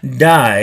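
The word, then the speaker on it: die (δεῖ): Now that word must is a Greek word, die. It sounds like die. In the Greek it's pronounced D-E-I, but it sounds like our die. Die, that is D-I-E.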